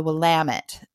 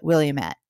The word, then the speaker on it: Willamette: We have a river that runs through our city called the Willamette, but if you're not local, you're going to go ahead and pronounce it Williamette.